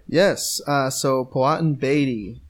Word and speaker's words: Powhatan: According to this video on YouTube that says, that's how to pronounce the name of the tribe, it's Powhatan. – Powhatan.